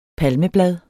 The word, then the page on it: palmeblad: dansk